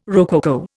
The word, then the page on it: rococo: Indian